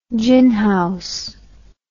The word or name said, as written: ginhouse